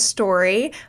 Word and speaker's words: Storri: So, like you said, my name is Story. It's spelled S-T-O-R-R-I.